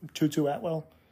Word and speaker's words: Tutu Atwell: No, I know it says Jatarius, but whenever they do the announcers, when they say his name, when he catches it, they say Tutu Atwell. They call him Tutu.